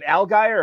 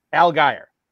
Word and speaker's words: Allgaier: He wants to know, do Damian Pierce and Tyler, and I'm going to butcher this, Farrell, is it Allgaier or Allgaier? – I think the first time right. – Allgaier.